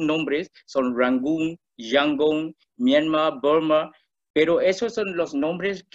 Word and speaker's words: Burma: Entonces Bama a Burma y escribe B-U-R-M-A Burma, antes eh, se llamaba Burma.